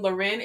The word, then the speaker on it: Lauren: You can find me on Instagram at Love Evolves, or you can type in my name, Loren. It's spelled Lauren, L-A-U-R-E-N, F Walker. And my Instagram handle is Love Evolves, L-O-V-E-V-O-L-V-Z.